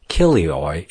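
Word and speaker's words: kilioi (χίλιοι): Let us also consider the word thousand, pronounced kilioi in the Greek.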